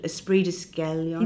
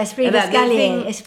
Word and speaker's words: esprit d'escalier: I can't speak French, but esprit d'escalier. – Yeah, esprit d'escalier. – How do you say it? – Esprit d'escalier.